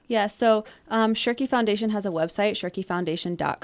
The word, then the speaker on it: Schoerke: and that is the Shirky Foundation. Is that the way to pronounce it? – That's actually the perfect pronunciation, the Shirky Foundation. – Shirky, well, let's spell it for people. S-C-H-O-E-R-K-E.